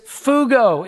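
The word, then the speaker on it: fugo (φεύγω): Now, the Greek word for this is fugo is what it means, or what it say, how it's pronounced in Greek.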